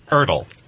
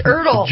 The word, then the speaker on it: Ertl: who is a – did I pronounce that right? – No. – Ertl? – But nobody ever does. – Well, how do you pronounce it? – Ertl. – Ertl. – I don't like that one either. – Kind of like a hurdle, but it's Ertl.